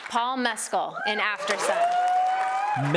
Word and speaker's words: Mescal: It's an Irish. It's not going to be Mescal. Mescal. It's not. You got to say it like if you were saying an Irish accent.